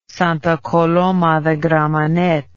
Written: Santa Coloma de Gramanet

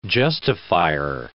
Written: justifier

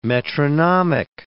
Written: metronomic

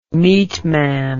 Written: meatman

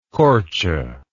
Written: korce

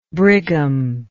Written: brigham

